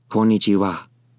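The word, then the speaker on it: Konnichiwa (こんにちは): Konnichiwa. Once again, konnichiwa. That is how you say hello in the afternoon in Japanese.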